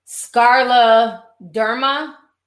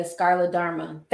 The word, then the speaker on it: scleroderma: Okay, so the disease is pronounced scleroderma. Okay, scleroderma.